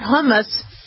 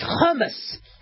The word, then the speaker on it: hummus: It's how you pronounce it, by the way. It's not hummus. It's hummus.